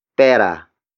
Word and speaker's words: pera: And that is pera. Pera. Pera. Pera is spelled as P-E-R-A.